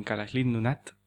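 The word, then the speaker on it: Kalaallit Nunaat: Hay un problema muy grande en Groenlandia, en Kalaslindunat que se dice, que es como lo dicen ellos.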